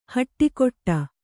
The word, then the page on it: ಹಟ್ಟಿಕೊಟ್ಟ: haṭṭikoṭṭa; Kannada